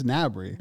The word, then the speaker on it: Gnabry: The G is silent, I think. Like Serge Gnabry. – Oh, Gnabs. – Is it Gnabry? I think it's Gnabry.